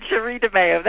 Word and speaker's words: Sherre DeMao: You did, Sherre DeMao.